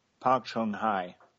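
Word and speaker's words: Park Geun-hye: Park Chung-hai. – All right, very, very impressive. I tried to rehearse that, and I couldn't get it to come out right. So thank you for pronouncing right.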